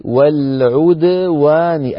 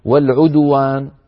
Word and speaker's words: والعدوان: والعدوان، يعني الشفتان تنضمان عند العين وعند الواو التي بعد الدال، أما عند الدال فلا، لا نقول والعدوان، لا نقول والعدوان، وإلا تصير الدال مضمومة، الدال ساكنة مقلقلة.